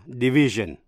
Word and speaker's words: division: Những danh từ tương đương là division, chữ S đọc là J.